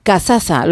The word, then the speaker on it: Cazaza: Bueno, seguimos con Cazaza.